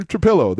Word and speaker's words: Trapillo: and figure out, oh, okay, it's actually Trapillo. The L, you don't have to roll the L.